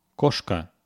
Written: koshka

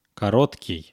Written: korotkyy

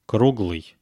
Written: kruglyy

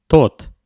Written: tot

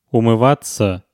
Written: umyvatsa

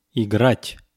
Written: igrat

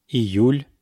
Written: iyul

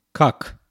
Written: kak

